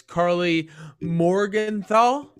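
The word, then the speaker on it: Karli Morgenthau: is coming in as Carly Morgenthau. Yeah, I think I said that right, guys. – I think you got it, yeah.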